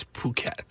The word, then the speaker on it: Phuket: It's definitely a long U. – Phuket. – Ooh. Or yeah. But it's not an sound. – It's ooh, not Because you want to make sure you don't pronounce it with the sound.